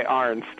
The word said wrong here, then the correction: Arzt: A-R-Z-T. Arst. Sorry, man. Name's hard to pronounce.